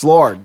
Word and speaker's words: Lorde: I'm pretty sure it's Lorde. – It's Lorde. – L-O-R-D-E. – Yeah. – She's known for that song, Royals. – I'm going with Lorde. – Lorde.